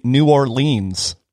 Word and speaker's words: New Orleans: So from you know like a total West Coast kid, like I say New Orleans. Is that that's probably not correct? – No, you've got way too many. You got way too many syllables in there. It's New Orleans.